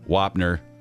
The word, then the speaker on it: Wapner: Let's do this one more time, just in honor of Judge Wapner. That's how you say it, right, Brittany? – Yep.